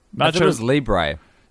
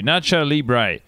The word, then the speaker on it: Nacho Libre: Nacho Libre. Is that, am I saying that correctly? – Nacho's Libre. – Yep, that works too. Nacho Libre.